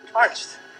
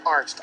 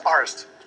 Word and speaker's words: Arzt: Let me ask you something, Arzt. Arzt. Arzt. No, not Arzt. Arzt. A-R-Z-T.